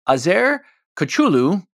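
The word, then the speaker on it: Azer Koçulu: Anyway, 2010s, an open source Turkish developer named Azer Kuchulu was cruising. And I hope I pronounced that even remotely close to correctly. – Sounds good.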